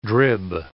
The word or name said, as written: drib